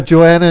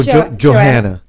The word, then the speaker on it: Joanna: So we have Joanna and we have, it's Joanna, right? Or Johanna. – Joanna Ray. – Okay, so we don't pronounce the H.